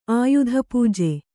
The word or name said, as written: ಆಯುಧಪೂಜೆ